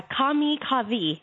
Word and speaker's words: Kami Kavi: But I think it's Kami Kavi. Am I saying that right? – Oh, yeah. Kami Kavi. Yes.